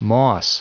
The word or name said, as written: moss